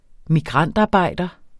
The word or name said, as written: migrantarbejder